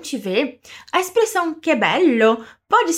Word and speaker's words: bello: Você já deve se lembrar que quando temos uma dupla consoante, o som dela é mais alongado, né? Então vamos treinar só essa parte. Fala pra mim. Bello. Bello. Ótimo!